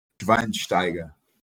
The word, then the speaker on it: Schweinsteiger: Faz a pronúncia certa do Schweinsteiger. É Schweinsteiger? – Schweinsteiger. – Schweinsteiger. Aí, pra que vocês que não aprenderam alemão ainda, tá aí, pô.